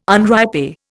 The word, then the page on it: unripe: Indian